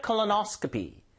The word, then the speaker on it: colonoscopy: Get a colonoscopy. Get a colonoscopy. 강세가 여기 들어갑니다. Get a colonoscopy.